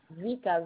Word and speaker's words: Vika: Vika, V-I-K-A. – Alors, V-I-K-A. Ok, comme ça se prononce, du coup. – Oui, exactement.